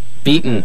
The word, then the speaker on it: Beaton: Did I pronounce the last name properly? – Beaton. – There we go.